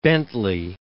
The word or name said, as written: Bentley